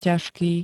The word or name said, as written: ťažký